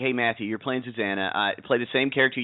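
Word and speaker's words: Zsuzsanna: Z-S-U-Z-S. – Right. – So it's Susanna, but the S's are Z-S's instead.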